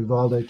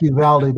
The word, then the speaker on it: Uvalde: Um, you know, we uh, I saw a report today that Uvalde is that how we pronounce that word, Uvalde, Texas? – Well, I uh, being from Texas, we always pronounce it Uvalde, but uh, I'm pretty sure I've heard every single variation at this point.